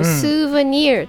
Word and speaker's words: souvenir: はい、 I will buy you a souvenir と言います、はい、お土産はちょっと発音が独特なんですけど、 souvenir と言います。